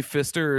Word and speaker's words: Pfister: Is it actually like literally Pfister, or is there one of those silent P's on the beginning? – There is a silent P. Yep. Mm-hmm.